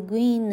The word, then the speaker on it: Gwynne: SpaceX 还有第三个非常重要的人，她是一位女性，叫做 Gwynne Shotwell。哦，她的名字非常难拼了，Gwynne，G W Y N N E，所有的字都是子音，而且最后那个 E 还不发音呢，多厉害！啊，最后这个第三个字母 Y 要念 E，所以念作 Gwynne，Gwynne。好，这个 Gwynne 呢，他也是工程师。